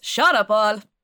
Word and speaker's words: Sean Paul: So it's Rockabye with Sean Paul and... Yep. – Sorry, you actually said it wrong. It's Shut Up All.